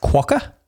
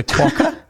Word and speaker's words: quokka: It's pronounced quokka. The animal is a quokka and it's uh-huh.